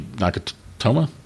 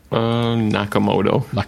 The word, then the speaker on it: Nakamoto: So the number of Bitcoin, like it started off when Bitcoin was, you know, like Satoshi Nakamoto. I always get his name wrong. – Nakamoto.